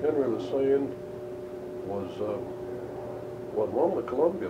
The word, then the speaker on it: Lesesne: And then a guy named Henry Lesesne, L-E-S-E-S-N-E. – Lesane. Yeah, that's the way they pronounce it. – Lesane.